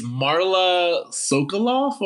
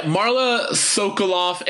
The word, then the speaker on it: Sokoloff: Yeah, because one of the actresses is Marla Sokoloff, or how do you say that? – I could never say her name. – Yeah, Marla Sokoloff. It's S-O-K-O-L-O-F-F for anyone who's trying to Google it.